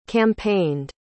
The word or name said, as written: campaigned